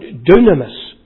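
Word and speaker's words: dunamis (δύναμις): It's the word dunamis. Now, it's spelt, using English characters here, D-U-N-A-M-I-S.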